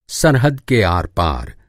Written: आर-पार

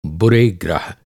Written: ग्रह

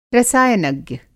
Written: रसायनज्ञ

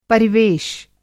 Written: परिवेश

